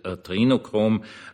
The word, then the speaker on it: Adrenochrom: Sag ich gleich, primär wird es ja englisch ausgesprochen, also Adrenochrom, es ist immer dasselbe.